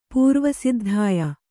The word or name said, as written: ಪುರ್ವಸಿದ್ಧಾಯ